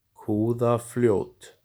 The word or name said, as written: Kúðafljót